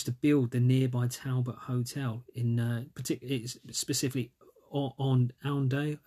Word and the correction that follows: Oundle: I can't pronounce it, Oundale, Oundle, in Northamptonshire.